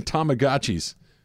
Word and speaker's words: Tamagotchi: That and Tamagotchis. – Yeah. – Did I say that right? – Yes, Tamagotchi.